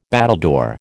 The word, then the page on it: battledore: American English